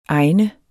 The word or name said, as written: egnet